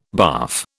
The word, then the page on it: barf: British English